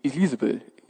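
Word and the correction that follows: Isebel: e, Isabel, ihr wisst das besser, Isabel, so spricht man es aus, ja?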